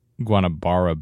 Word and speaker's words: Guanabara: They've got a picture here of the contaminated waters in Guanabara Bay.